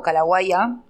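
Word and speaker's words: Kallawaya: sobre todo el foco está en la cultura calaguaya o calaguaya, como, se pronuncia correctamente,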